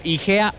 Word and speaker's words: Hygea: Están como IGEA Óptica. IGEA se escribe H-Y-G-E-A. – Exacto, correctamente.